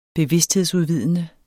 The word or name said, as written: bevidsthedsudvidende